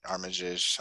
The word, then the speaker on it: Armitage: Armitage something, yeah.